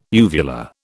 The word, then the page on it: uvula: British English